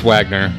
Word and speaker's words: Wagner: And I believe it's Wagner, not pronounced like the composer.